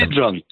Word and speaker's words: Sidran: One, just a point of clarification, and forgive me for mispronouncing it, but you said something with the Sidran Foundation, or I missed that. – Sidran. S-I-D-R-A-N is a foundation that has paid close attention to this, yeah.